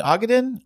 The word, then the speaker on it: Ogden: Ogden, Utah. – Ogden, Utah. Now, another Utah on this list. It says it's north of Salt Lake City. So I know nothing about Ogden.